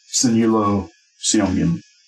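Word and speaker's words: Sineulo Seomgin: S-I-N-E-U-L-O space S-E-O-M-G-I-N. Sinyalo Siomgin.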